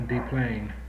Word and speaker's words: Des Plaines: A Des Plaines.